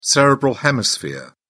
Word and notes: cerebral hemisphere: British English

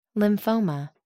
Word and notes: lymphoma: American English

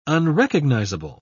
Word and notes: unrecognizable: British English

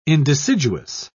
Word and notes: indeciduous: British English

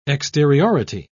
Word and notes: exteriority: British English

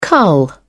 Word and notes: cull: British English

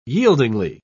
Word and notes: yieldingly: British English

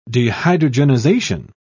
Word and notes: dehydrogenization: British English

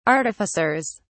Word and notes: artificers: British English

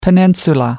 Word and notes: peninsula: American English

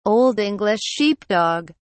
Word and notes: old English sheepdog: British English

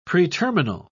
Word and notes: preterminal: British English

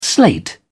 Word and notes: slate: British English

